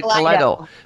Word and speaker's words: KALEIDO: You are with the band, is it KALEIDO, right? I pronounced it right? KALEIDO. Thank you. No, it's not EO.